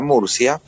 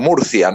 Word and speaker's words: Murcia: Ella como tengo ciudadanía española, ella pudo también obtener pasaporte español y llevarla a Murcia, o Murcia, ¿no? Como pronuncian los españoles, para instalarse en su universidad.